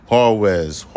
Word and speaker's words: Juarez: I know you can't say the J. The J is silent. When you, Juarez, Mexico.